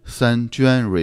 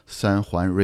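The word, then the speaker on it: San Juan: 它这个名字呢，如果按照拼音来发音的话呢，就是像三娟 River。不过据说按照当地这个有点像西班牙语这个发音呢，应该叫成三环 River。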